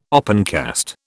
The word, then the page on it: opencast: British English